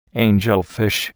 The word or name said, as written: angelfish